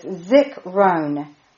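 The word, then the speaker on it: Zikrone (זכרון): It's Zikrone. Z-I-K hyphen R-O-N-E with the emphasis on the second part, R-O-N-E.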